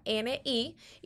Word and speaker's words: Colombani: Pues mira, en Facebook pueden conseguirme por Michelle López Colombani. Se escribe Michelle López. El apellido Colombani es Colombani.